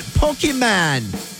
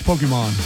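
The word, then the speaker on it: Pokémon: What about Pokemon? Did I say that right? – No, it's Pokemon.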